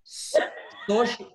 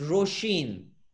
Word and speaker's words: Roisin: It's pronounced Sosh. No. That's yeah, it's, pronounced, it's pronounced Roshin.